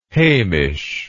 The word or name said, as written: heimish